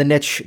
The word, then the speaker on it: niche: Which the fear of the niche down, which yes, it's niche, it's not niche because niche rhymes with rich.